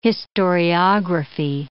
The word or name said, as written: historiography